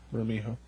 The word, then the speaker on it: Bermejo: Bermejo.